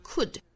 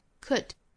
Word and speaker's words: could: 那我们首先看 if 和 I 连读，我们读成 if I，后面的 could 一定要注意不要读成了 could，应该它是一个 could could，那么它是一个短元音口型稍松。